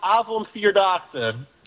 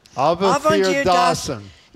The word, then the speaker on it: Avondvierdaagsen: It's pronounced Avondvierdaagsen. – Avondvierdaagsen.